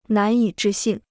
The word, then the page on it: 难以置信: nán yǐ zhì xìn